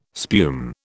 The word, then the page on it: spume: British English